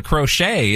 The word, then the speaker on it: crochet: The only thing more magical than being able to crochet is the silent T in crochet.